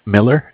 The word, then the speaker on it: Miller: Well, my name is Zach, spelled Z-A-C-H. – And your last name, please? – Miller, M-I-L-L-E-R.